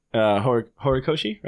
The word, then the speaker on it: Horikoshi: And then one other thing I'll point out is I saw My Hero, Horikoshi, right? That's how you say it? – Yeah.